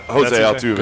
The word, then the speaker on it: Jose Altuve: Yeah, Jose Altuve.